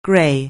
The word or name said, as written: grey